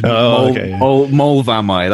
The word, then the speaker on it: Moel Famau: I found out recently, I used to live in North Wales, and I used to live on the side of a mountain called Mol Vamai. So that's spelled, for anyone who wants to look it up, M-O-E-L-F-A-M-A-U. Yeah, I'm pretty sure that's how it's spelled. – Because that's how you spell Mulvaney. – Well, Welsh is a weird language. Mulvaney, that's how you spell it.